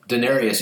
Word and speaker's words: Daenerys: did I say that right? – No. Daenerys, yes. The last part.